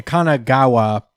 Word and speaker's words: Kanagawa: Um, Meanwhile, at the Kanagawa Prefecture,